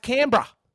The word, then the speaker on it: Canberra: Now, our nation's capital is called, if you want to read our nation's capital, it looks like it's spelt Canberra. But no. It's pronounced Canberra. Canberra.